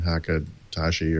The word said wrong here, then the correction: Takahashi: Dean Takahashi.